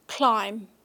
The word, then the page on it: CLIMB: British English